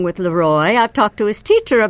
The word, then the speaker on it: Leroy: Leroy, that's how you pronounce it.